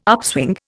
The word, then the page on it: upswing: Indian